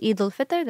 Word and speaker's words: Eid al-Fitr: So the holiday that Ramadan ends with is called Eid al-Fitr. That's how we'll say it in Urdu.